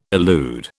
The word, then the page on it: elude: British English